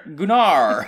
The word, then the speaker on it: Gunnar: It's G-U-N-N-A-R. I just can't remember how to pronounce it. – It's not Gunnar. – Gunnar!